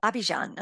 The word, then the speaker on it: Abidjan: Is it Abidjan? Is that how you pronounce that? – Abidjan, Yeah.